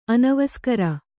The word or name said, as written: ಅನವಸ್ಕರ